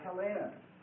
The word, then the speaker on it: Hellenes: Spelled H-E-L-L-A-S. And they called themselves the Hellenes. They called themselves the Hellenes. That's spelled H-E-L-L, long E, N-E-S, and that's translated in the New Testament as Greeks.